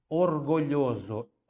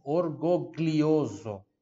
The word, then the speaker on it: orgoglioso: Quem está me, me, ouvindo pelo podcast, olha só, orgulhoso, O-R-G-O, orgo, depois tem o GLI, onde tem esse som de, de LHI, tá? Orgulhoso, né? Se eu fosse ler isso aqui, se eu não soubesse como se fala isso aqui em italiano, eu ia ler assim, orgoglioso, tá? Então, para você aprender que esse GLI tem som de LHI.